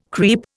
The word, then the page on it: creep: Indian